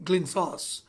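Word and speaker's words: Glyntzos: And the way they would pronounce it is Glynos, which is actually technically Glynsos. All right, it's G-L-Y-N-T-Z-O-S.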